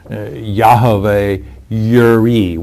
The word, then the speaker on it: Yahweh Yireh: And Jireh transliterated into English is J-I-R-E-H. That is, though, a transliteration. It's not in the original Hebrew. Properly pronounced, the name you mentioned is Yahweh Yireh with a Y.